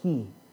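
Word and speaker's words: ki (כי): In the Hebrew, the original language, the word is pronounced ki.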